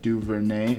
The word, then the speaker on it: DuVernay: Yeah, and just so you guys know, we're talking about When They See Us on Netflix, the Ava DuVernay, is that how you pronounce her name? – Yeah.